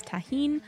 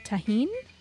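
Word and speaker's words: tahini: And that was a mango popsicle with, I think it's pronounced tahine, chamoy, and garnished with a slice of lime.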